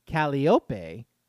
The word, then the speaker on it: Calliope: Don't read ahead for this answer, but how do you think, quote, Calliope Street is pronounced? A musician like yourself probably said it's like the big organ, which I just did, and you could find them on steamboats. It's really Calliope, like the last name of the Kentucky basketball coach.